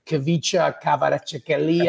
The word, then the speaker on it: Kvaratskhelia: Kvicha Kvaratskhelia. Yeah, just Kvara. Just Kvara. He made it easy for all of us. He told us the proper pronunciation and then says, I go by Kvara.